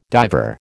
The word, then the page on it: diver: American English